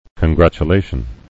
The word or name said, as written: congratulations